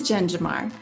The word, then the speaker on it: Jen Jamar: It is Jen Jamar.